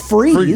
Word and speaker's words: Fries: Fries, F-R-I-E-S. You can eat those back in Virginia, back north. It's actually pronounced freeze.